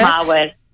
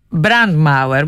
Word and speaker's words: Brandmauer: Δηλαδή, δεν το γνωρίζω γερμανικά, δεν ξέρω πώς να το προφέρω. Πάντως, αφορά ένα τείχος που υψώνεται. – Brandmauer. – Brandmauer, μάλιστα. Κυριολεκτικά είναι το τείχος που υψώνεται με σκοπό να περιορίσει την εξάπλωση μιας πυρκαγιάς από το ένα σπίτι στο άλλο.